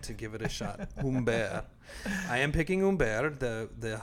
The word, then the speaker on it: Humbert: And is that how you pronounce his name? – Humbert, yeah.